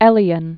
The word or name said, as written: Elion